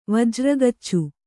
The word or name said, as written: ವಜ್ರಗಚ್ಚು